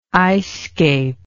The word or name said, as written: icescape